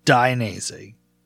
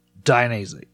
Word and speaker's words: Dainese: Just Dainese. It sounds hard to say until you say it. Dainese.